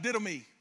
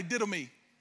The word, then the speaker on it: didomi: The actual meaning, because this is the word in the Greek for give, it's simply pronounced didomi. Everybody say didomi.